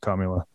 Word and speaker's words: Kamala: By the way, to the guy who had the comment about the pronunciation of Kamala, thank you for chiming in. – Kamala. – That's right. Clearly, it's Kamala.